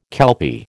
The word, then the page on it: kelpie: American English